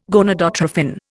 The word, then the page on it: gonadotrophin: Indian